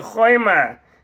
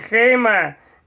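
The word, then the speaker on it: хойма (חומה): Как сказано, что с одной стороны и с другой стороны море было хойма, стена. Но слово «стена» пишется здесь без буквы «вов». Почему буква «вов» отсутствует? Потому что это слово не читается как «хойма», можно его читать как «хейма».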